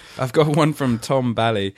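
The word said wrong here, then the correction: Balie: Tom Bly. – I'll get that eventually. Tom Bly.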